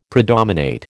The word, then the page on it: predominate: American English